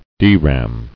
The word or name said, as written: Dram